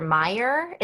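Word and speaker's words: Meijer: I believe it's pronounced Peter Meyer. It's M-E-I-J-E-R.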